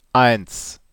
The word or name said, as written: eins